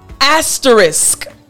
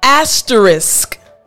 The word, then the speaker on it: asterisk: So after me, asterisk. I told you the pronunciation is tricky. Again, after me, asterisk.